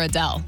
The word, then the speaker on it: Adele: I mean, you see the name Adele, A-D-E-L-E.